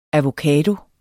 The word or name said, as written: avocado